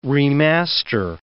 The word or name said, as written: remaster